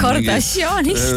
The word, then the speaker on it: Kardashianist: Kardashianist mingi... no niimoodi tuleb hääldada , ikkagi Armeeniast on ju , kellel on mingi 10 lapsehoidjat on ju .